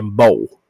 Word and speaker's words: beau: OK，B E A U 那个一是不发音的，这个字念 bow。